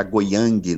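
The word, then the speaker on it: Goyang: Eu tive até o cuidado de ouvir a pronúncia Goiân, que está Goiang, né? Falei, tem que ouvir essa pronúncia para não falar errado.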